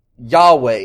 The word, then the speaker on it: Yahweh: Yahweh is how we pronounce it sometimes.